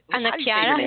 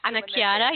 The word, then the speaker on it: Anna Chiara: It's Anachara. Is it Anna Chiara? Yeah. – Anna Chiara. Okay. – Anna Chiara. Yes, that's Italian. Anna Chiara.